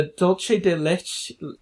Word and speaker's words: dulce de leche: Um, desserts include a dulce de leche. How do you say that? – Oh, dulce, dulce de, de leche. leche.